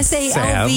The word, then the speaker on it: salve: No one pronounces the L in salve. It's salve.